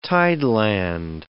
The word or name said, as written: tideland